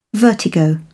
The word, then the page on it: vertigo: British English